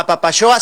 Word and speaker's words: Apapaxoa: Entonces, la invitación, sí, abierta a Apapaxoa, se escribe con X, Juan, ¿eh? Claro. Apapaxoa, se pronuncia como si fueras de Chihuahua, pero es Apapaxoa.